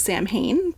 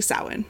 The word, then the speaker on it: Samhain: If I were to phonetically pronounce it, it comes out as Samhain. – Yes. – But it's actually Samhain.